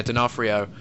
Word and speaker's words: D'Onofrio: D'Onofrio? – Yeah, D'Onofrio is in New Orleans.